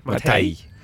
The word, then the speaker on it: Matej: Matej? Zou je het zo uitspreken? – Matej.